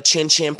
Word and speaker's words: XinXin: Yeah, that's how you pronounce it. So I need everybody to go to Chin Chin Planet on Instagram. X-I-N-X-I-N Planet.